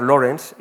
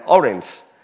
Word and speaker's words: Lawrence: Como, en la, como película que siempre todos eh, no, sabían pronunciar Lawrence y decían Orange.